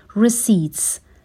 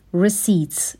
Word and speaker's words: receipts: receipts به معنای رسیدها هستش و پی توی این کلمه اصلا تلفظ نمیشه. receipts، receipts به این شکل تلفظ میشه.